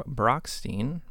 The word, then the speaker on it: Brochstein: So keeping on track with the topic of instruments, next we're going to hear from Herb Brochstein. Brochstein. I never know if it's Stein. You second-guessed yourself. Yeah. I saw that. – You said it right the first time.